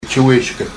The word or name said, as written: gchi-weshkad